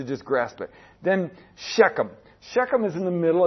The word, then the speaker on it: Shechem: And it's also called Sychem. Sometimes it's spelled Sychem. S-Y-C-H-E-M. But it's the same place, Shechem.